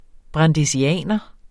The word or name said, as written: brandesianer